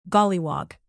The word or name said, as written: golliwog